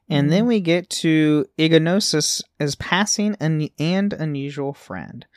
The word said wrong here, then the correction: Ignotus: So Ignotus.